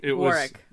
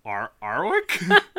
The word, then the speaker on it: Warwick: Yeah, but and because it's Warwick Davis, Warwick. – Yes. Oh yes. Yes. – He gave instructions. – Don't pronounce the W. Arwick? No, the second one.